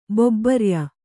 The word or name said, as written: ಬೊಬ್ಬರ್ಯ